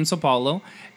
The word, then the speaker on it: São Paulo: And yeah, this is a duo from Brazil, from São Paulo.